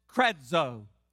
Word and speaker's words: kradzo: Now this word, the Greek word is pronounced kradzo, K-R-A-D-Z-O. Kradzo, that is the transliterated Greek word for believe. The Greek word pronounced kradzo is also, or not believe, but scream, to call out.